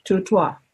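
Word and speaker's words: Dutsu: And it's actually spelled D-U, okay, T-S-U, and it's pronounced tutoi. Almost what you hear the frog say, tutoi, tutoi, okay?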